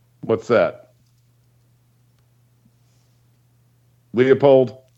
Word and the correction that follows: Leipold: Lance, say it right, Leipold.